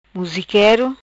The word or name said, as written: Musiquero